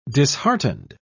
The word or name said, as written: disheartened